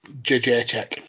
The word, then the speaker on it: Jędrzejczyk: Jędrzejczyk.